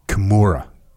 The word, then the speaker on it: Kimura: What's the proper? – Kimura. – Kimura. What am I messing up? – The U. You say Kimura. – Oh, Kimura. – Kimura. – Kimura. – Yeah. – Kimura.